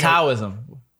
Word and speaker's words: Taoism: Taoism. – It's actually supposed to say with a D, Taoism. – Taoism. – It's spelled with a T, you're supposed to spell with a D.